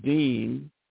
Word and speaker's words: Deen: So in the Holy Quran, Allah or God, Almighty God, describes the religion of Al-Islam as Deen Al-Fitra. It's pronounced as though it was spelled D-E-E-N, Deen. Al-Fitra, Al, A-L, and Fitra, F-I-T-R-A-H, which means the religion established on the laws and principles which uphold creation.